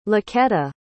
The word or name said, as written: laqueta